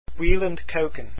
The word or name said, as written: kuijken